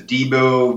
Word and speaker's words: Debo: The family nickname is Debo, D-E-B-O.